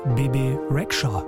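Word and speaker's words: Bebe Rexha: Bibi Rexha, oder wie sie original auf Albanisch wohl ausgesprochen wird, Bibi Rexha.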